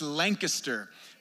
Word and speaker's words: Lancaster: It's pronounced Lancaster, Pennsylvania.